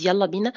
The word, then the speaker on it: Yalla Bina: It's called Jalla Bina. It's a Swedish program. It's Yalla Bina. In fact, its pronunciation is Yalla Bina. Yalla Bina in Egypt means let's go.